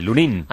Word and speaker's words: Lunin: Que se dice Lunin. – Con acento en la I, Lunin.